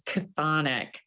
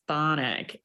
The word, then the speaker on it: chthonic: The British pronunciation is chthonic. The American pronunciation is thonic.